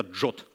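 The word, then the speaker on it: JWT: Как правило, это JSON Web токен или еще читается это JOT токен.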